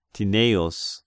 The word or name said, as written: Tineius